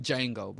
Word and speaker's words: Django: The D is silent, so it's really Django, but whatever.